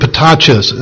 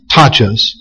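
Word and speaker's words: Patachos (πτωχός): The word for poor in this phrase, or in this statement, poor is taken from the Greek word patachos. I'm sure that the P is silent. Patachos. P-T-O-C-H-O-S. Patachos.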